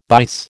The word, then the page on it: bice: American English